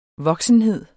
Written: voksenhed

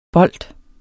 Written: bold

